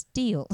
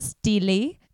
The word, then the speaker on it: Steele: I asked her to cast me, a unknown actress at the beginning of her career, in the role of Anastasia Steele, which apparently is pronounced Steele. – Yeah. I thought you were doing that for effect. – No. Someone told me after the show, they said it's Steele.